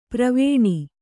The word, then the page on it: ಪಾರ್ವಣ: pārvaṇa